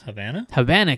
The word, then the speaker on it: Havana: At the American Embassy in Bahana, Cuba? Is that how it's pronounced? – Havana, Cuba.